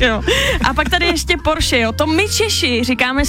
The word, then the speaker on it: Porsche: Všichni říkají Porsche špatně, ale je to opravdu Porsche, takže tady si můžeme jako poklepat na rameno, že to říkáme dobře.